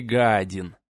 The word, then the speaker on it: Гадин: Магистра звали Гадин. Именно так, с ударением на первый слог.